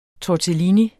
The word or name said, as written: tortellini